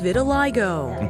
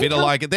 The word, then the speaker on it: vitiligo: Like Sonia, Barbie has vitiligo. – Okay, can vitiligo, there we go, that's how it's said.